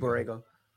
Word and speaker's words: Borrego: They fired James Borrego. I think I'm saying that right? – Yeah. – James, I think it's Borrego. He just got fired.